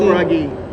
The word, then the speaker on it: Scroggie: Chad Scroggie. – Scroggie? S-C? – Scroggie. – How do you spell that? – S-C-R-O-G-G-I-E.